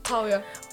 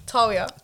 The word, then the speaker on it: Tawiah: Tawiah. – Oh gosh. I'm so annoyed that I'm so bad with names. I'm so sorry. I want to say that again. Leoni Tawiah.